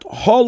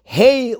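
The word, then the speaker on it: Hey (הא): So firstly, the Rebbe writes that Hey should be pronounced with a Tzedei and not with a Kamatz, not Ho, Lachma, Anyo, but Hey, Lachma, Anyo, meaning this is the bread of affliction.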